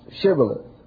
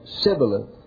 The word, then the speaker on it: Shibboleth: make them pronounce Shibboleth. And they said Sibboleth, which identified them as the tribe they were fighting against, because they couldn't say the H sound.